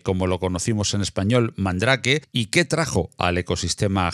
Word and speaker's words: Mandrake: Bueno, pues eh, entramos en materia directamente porque no podemos hablar de Gael sin por lo menos mencionar a Mandrake o Mandrake, que creo que se pronuncia algo más parecido en francés.